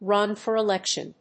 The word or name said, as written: run for election